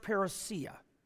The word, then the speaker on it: parousia: There's an interesting Greek word, and it's pronounced parousia.